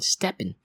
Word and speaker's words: STEPN: Now personally, Claire, I am not a fan of this app called Steppin. Now it's spelled as S-T-E-P-N.